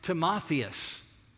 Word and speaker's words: Timotheus: In the Greek, that name Timothy is pronounced Timotheus, and it means honoring God.